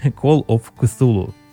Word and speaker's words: Call of Cthulhu: Call of Cthulhu.